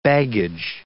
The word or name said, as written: baggage